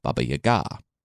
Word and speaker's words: Baba Yaga: who's actually pronounced Baba Yaga, which from now on I'll say that, but I didn't want to mess it up for the intro.